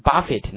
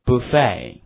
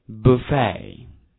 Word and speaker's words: buffet: 那么自助餐这个词叫 buffet，那么很多同学把它读成 buffet，那是不对的哈。那么这个是个法语词，词尾这个 et 是个字母组合，读成 a 的音哈，所以说读成 buffet buffet。